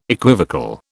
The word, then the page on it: equivocal: British English